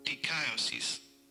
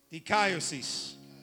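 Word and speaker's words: Dikaiosis (δικαίωσις): I'm going to let Google say it because I say it incorrectly every time. Dikaiosis. Dikaiosis.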